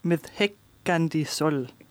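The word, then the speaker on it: Með hækkandi sól: Ja, nu skal jeg se, om jeg kan udtale det, men det er Islands bidrag på 2022 med gruppen Systur med sangen Med Heggandi Sol, tror jeg. – Det tror jeg er udmærket gjort.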